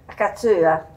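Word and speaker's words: cassoeula: Cassuela. Lo sapevo. – Cassuela. – Io sì. – Pronunciala bene. – Cazzuela.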